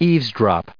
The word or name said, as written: eavesdrop